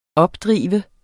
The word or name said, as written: opdrive